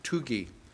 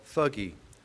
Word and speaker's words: Thuggee: Actually, it's pronounced Tugi, but you'll recognize the word if I pronounce it the way it's spelled, Thuggee.